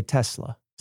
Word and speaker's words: Tesla: But I say Tesla. – Same. – With a nice soft S.